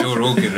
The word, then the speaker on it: Joe Rogan: I said the name correctly. Right? – Yeah, Joe Rogan.